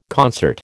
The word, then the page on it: concert: American English